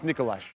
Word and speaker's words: Nikolaj: It's pronounced Nikolaj.